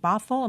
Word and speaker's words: Bothell: He's a researcher, founder of the Center for Reinventing Public Education at the University of Washington, Bothell. Am I pronouncing that correctly? – That's all correct.